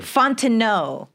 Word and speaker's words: Fontenot: Three syllables. Guys, Fontenot. Three syllables.